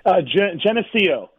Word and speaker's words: Geneseo: Terry Schilling is a native of Genesco. Did I say that right, Terry? – Geneseo. – Geneseo, okay. I didn't have the right inflection there.